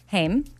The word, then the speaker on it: Haim: Outway with Amy and Lisa Hayem. – Haim. – Yep. Yeah. It's spelled Haim.